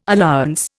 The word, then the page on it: allowance: Indian